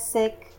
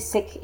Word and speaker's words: Sikh: In Punjabi, Sikh is the right way to say it. – Okay. – It's a smaller I, not the double E sound.